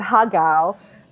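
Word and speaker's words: ha gao (蝦餃): We're gonna do Chinese food, and when it came to H, like my husband who's Cantonese, he said ha gao, and which is it's like it's not an H word, but that's how you say it in Cantonese, and mm-hmm.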